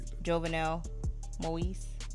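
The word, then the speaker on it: Jovenel Moïse: His name is Jovenel Moise. Mm. It's a French name, so I butchered that. But anyway, no, I didn't butcher it. Jovenel Moise.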